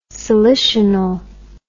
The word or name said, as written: salicional